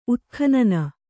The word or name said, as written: ಉತ್ಖನನ